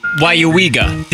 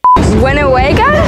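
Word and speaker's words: Weyauwega: It's pronounced Wyuwega. Did anybody get it? – No. – All right, here we go. Wyuwega. – Winnewega? – Wayuwega. – Wayawiga. – Not even close.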